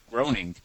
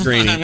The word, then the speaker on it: Groening: Now, this is all very interesting, but how did you pronounce his name? – Who? Matt Groening. – Matt Groening? – Yeah. How do you pronounce it? – I didn't know. I didn't know, so I always pronounced it Groening.